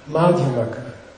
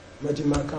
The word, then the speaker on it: Madhyamaka: Madhyamaka. Madhyamaka. So, anyway, you gotta have a long A in the front. If you're gonna have a long A, it's gotta be in the first syllable, okay?